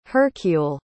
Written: hercule

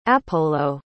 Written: appolo

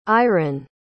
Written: iren

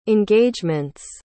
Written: engagements

